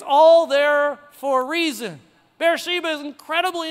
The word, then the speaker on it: Beersheba: Beersheba.